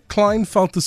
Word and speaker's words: Klinefelter: But today we're chatting about Klinefelter syndrome. Did I say it right? – Yes, you did.